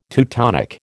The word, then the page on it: Teutonic: American English